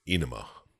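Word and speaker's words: Ænima: If I listen to Mr. Jaeger, it's Enema. – It probably is. – Enema? – Like yeah, the A-E is E.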